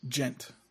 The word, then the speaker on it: djent: D-J-E-N-T. – Yes. – And it's pronounced what? – Gent. The D is silent.